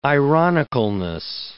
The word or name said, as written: ironicalness